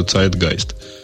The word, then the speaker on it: Zeitgeist: Ты про Zeitgast, да? Зидге. Он, по-моему, какой-то немецком надо говорить, да? Это немецкое, это немецкое слово, оно означает дух во имени, и поэтому, ага. соответственно, произносится Zeitgeist.